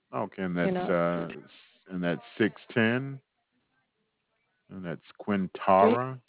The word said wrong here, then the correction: Quintard: Yeah, that's Quintara, Q-U. I may pronounce it incorrectly, but it's Q-U-I-N-T-A-R-D Drive. – Quintard, yeah. Okay.